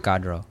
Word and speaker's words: Gadreau: Is it Gadreau? – Gadreau. – Okay. When you look at it, it looks like it'd be easier to pronounce, and then you actually go to try to say it, and you're like, your tongue just gets tied.